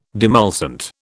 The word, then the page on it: demulcent: British English